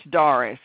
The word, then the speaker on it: Darius: Okay, it's Darius, and it's spelled D-A-R-I-U-S. – D-A-R-I-U-S, Doris. – Yes, Darius, however you want to pronounce it. – Exactly. May I have your last name, please? – Okay, it's R-A-W-L-S.